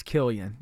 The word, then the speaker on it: Cillian: By the way, we've been saying his name wrong. – Who's? – Cillian Murphy. – Oh, Killian Murphy. Killian Murphy? – It's Killian. Yeah. – Oh, even though it's a C, huh? – I know.